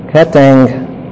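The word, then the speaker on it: Ketangelo (καταγγέλλω): Ketangelo. When you have two gammas come together, it's pronounced like a ng.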